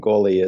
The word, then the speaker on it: Gourley: Most people, unless you're Scottish, you won't pronounce that right. So, Gourley is perfect.